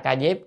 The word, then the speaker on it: Ca Diếp: Tôn giả Ca Diếp, chữ Diếp là Diệp vậy, nhưng mà chúng ta hay đọc là Ca Diếp nhé, mà đọc Diệp, Diệp là lá, cái lá cây gọi là Diệp.